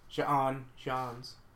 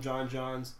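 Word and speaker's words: J'onn J'onzz: John Jones.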